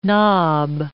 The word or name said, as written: nob